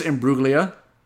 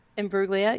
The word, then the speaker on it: Imbruglia: How do you say her last name? I'm sorry. – I think it's Imbruglia. – Imbruglia? Yeah. Sorry.